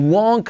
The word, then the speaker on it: won't: If you leave now, if you leave me now, if you leave now, you won't come back in time for dinner. If you leave now, you won't come back in time. You won't come back. La T de won't, no la pronunciamos.